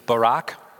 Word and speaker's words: barak: We saw this a couple weeks ago, but say it with me, Barak. Maybe a little more emphasis on the K, ready?